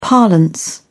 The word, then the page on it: parlance: British English